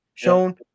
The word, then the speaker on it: Schoen: Schoen.